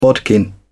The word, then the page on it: bodkin: British English